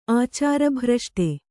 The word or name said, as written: ಆಚಾರಭ್ರಷ್ಟೆ